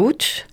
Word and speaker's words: Łódź: Il y a eu des échanges avec une académie supérieure de Łódź, c'est Łódź en français, oui. Ça se prononce Łódź en polonais.